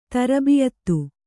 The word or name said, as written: ತರ್ಬಿಯತ್ತು